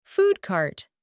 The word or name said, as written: food cart